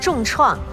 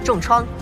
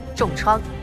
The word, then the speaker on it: 重创: 来看第二题，以下词语的正确读音为 A 重创， B 重创，请作答。来看正确答案， B 重创。